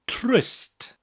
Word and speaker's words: trist: The word is trist. Trist. It's spelled T-R-I-S-T and sounds almost exactly like the English word tryst, except that R in the word rolls.